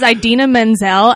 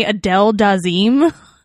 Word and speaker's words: Idina Menzel: Maybe she was really upset that her name got butchered. I would be. My name was Idina Menzel, and he called me Adele Dazeem.